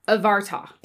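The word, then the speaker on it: Abhartach: Abhartak? A heart attack? – I know that BH pronounces differently. It's Avarta. – So it's a V sound.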